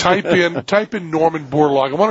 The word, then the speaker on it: Borlaug: You have a date for Norman Borlaug? – I believe September.